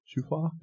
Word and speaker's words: shofar: Shofar? Is that, am I pronouncing that right? Shofar? How do you say it, Manny? I see you're shaking. Shofar? Okay, shofar.